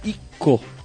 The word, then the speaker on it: Ico: Um pequeno adendo, Ico se pronuncia Ico.